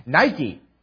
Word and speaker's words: Nike: The noun that's here translated as victory, the Greek word niki, occurs only here in the New Testament. And in case you're wondering, this is in fact the word from which, using a slightly different pronunciation, Nike, one of the world's largest suppliers of athletic shoes and apparels, takes its name.